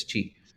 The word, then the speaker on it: Pixel Qi: It's called Pixel Qi. P I X E L Q I, but the pronunciation is Chi.